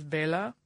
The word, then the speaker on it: Béla: It's because when I pronounce it correctly in the Hungarian language, which is Béla, I sound like the Count from Sesame Street.